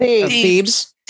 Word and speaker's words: Thebes: T-H-E-B-E-S. How would you say that? – Thebes.